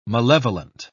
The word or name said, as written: malevolent